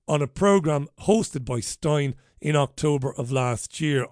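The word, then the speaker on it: Steyn: Anyway, it has ruled that GB News and a programme that was presented by Mark Stein, I think it's pronounced Stein, not Steen, Stein, did break Ofcom rules.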